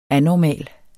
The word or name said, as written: anormal